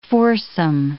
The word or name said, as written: foursome